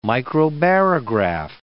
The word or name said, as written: microbarograph